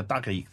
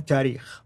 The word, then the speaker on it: tarikh: And then the last genre, the tarikh. How do you, how do you say it again? Tarikh. Tarikh. I have to make sure I say it correctly.